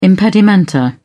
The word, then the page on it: impedimenta: British English